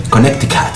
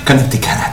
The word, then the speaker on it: Connecticut: Connecticut. Está bien, estuve practicando un poco. Connecticut.